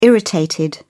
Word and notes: irritated: British English

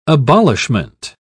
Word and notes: abolishment: British English

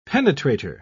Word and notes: penetrator: British English

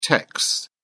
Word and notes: techs: British English

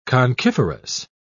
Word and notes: conchiferous: British English

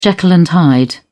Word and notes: Jekyll and Hyde: British English

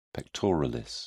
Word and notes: pectoralis: British English

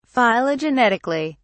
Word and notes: phylogenetically: British English

